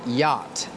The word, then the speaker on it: yacht: The next word is yacht This is a difficult word Because it has two letters that are silent. The two letters are C and H.